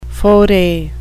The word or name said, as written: foray